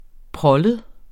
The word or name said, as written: prollet